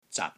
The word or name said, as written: ZAT6